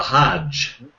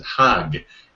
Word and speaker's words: Hajj: There's a modern word that many people know, which is the Hajj. H-A-J-J, it's often transliterated. Or in Egypt, you'd even pronounce it Hag. It would be H-A-G-G, like Haggai.